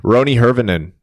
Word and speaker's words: Roni Hirvonen: Roni Hirvonen, her yeah, that's how you say it, right? Sure.